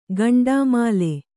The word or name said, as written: ಗಂಡಾಮಾಲೆ